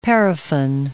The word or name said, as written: paraffin